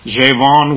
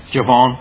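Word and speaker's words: Gjevjon: Tanya, these Scandinavian names are really hard to pronounce, but it's G-J-E-V-J-O-N, Javon, we'll say, Javon, is a lesbian filmmaker who intentionally made the comment to criticize Norway's hate speech laws.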